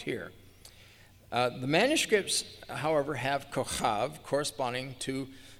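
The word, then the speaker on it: kochav (כוכב): Abraham 3:13 defines kochav, which is the correct Hebrew pronunciation, as star, and kochavim as stars, or all the great lights, which were in the firmament of heaven.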